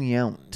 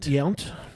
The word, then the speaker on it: Yount: So like, it's a tricky name to pronounce. Hampton Yount. – Yount. – Yount. Hampton Yount. Hampton Young. – It's the T's that get you.